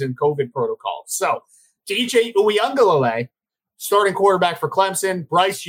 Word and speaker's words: Uiagalelei: DJ DJ Uiagalelei. That's how you pronounce it.